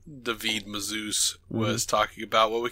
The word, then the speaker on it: David Mazouz: David Mazouz. That's the way I've been told that we have to say his name. – Nah, David Mazouz.